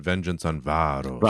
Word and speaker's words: Vengeance on Varos: By the way, I'm so sorry, just then I mispronounced that. I should have said Vengeance on Varos. I didn't correctly.